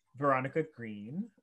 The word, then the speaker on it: Veronica Green: So next we have the Queen of Lancashire, Veronica Green. I'm probably butchering that name, but no, you did great.